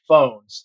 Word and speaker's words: Fownes: Oakmont comes around officially in 1903, and it was founded by Henry Fones. It's spelled F-O-W-N-E-S, but it is pronounced like telephone.